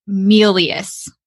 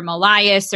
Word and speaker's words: Melious: And then I got married to this amazing man that has a last name of Melious. M-E-L-I-O-U-S. And I even remember when I first met him, I mispronounced his name. I thought it was Melious or Melias or something along those lines.